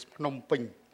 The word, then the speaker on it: Phnom Penh: We live in the capital of Cambodia, Phnom Penh, where the correct pronunciation is Phnom Penh.